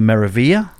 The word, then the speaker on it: Meraviglia: I heard seven different ways to pronounce MSC's second newest cruise ship, which is the Meraviglia. – Yeah, that's it.